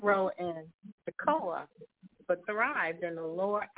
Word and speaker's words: Tekoa: And I think pronunciation where he came from is Tekoa, T-E-K-O-A. Am I pronouncing that right? – Mm-hmm.